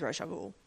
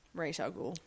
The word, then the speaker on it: Ra's al Ghul: He's Ra's al Ghul. Let's be real here. – Ra's al Ghul? – Ra's al Ghul. – Ra's al Ghul? – Mm-hmm. I pronounce it like the Batman animated series.